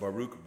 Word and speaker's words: Baruch: An individual by the name, I think it's pronounced Baruch Brody, he wrote a book that's called Abortion, small book, Abortion and the Sanctity of Human Life, a philosophical view.